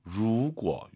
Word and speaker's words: 如果: 루, 루 아니에요. 알 발음 더 말아야 됩니다. 루, 루고 이거 기억하시고요.